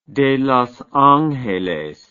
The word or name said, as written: de Los Angeles